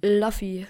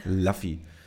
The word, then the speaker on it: Luffy: Luffy. – Luffy.